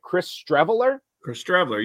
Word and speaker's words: Chris Streveler: Chris Streveler, you've got it right.